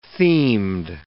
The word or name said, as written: themed